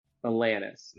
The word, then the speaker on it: Alanis: Alanis.